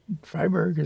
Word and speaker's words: Freiberg: Freiberg is yes, E-I, Free Mountain.